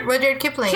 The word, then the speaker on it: Rudyard Kipling: Rudyard Kipling.